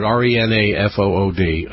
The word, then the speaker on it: Renafood: No, Rent-a-food, R-E-N-A-F-O-O-D. Again, Rena, as in Rena. Rena. – Some people pronounce it Rena-food.